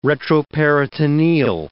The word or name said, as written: retroperitoneal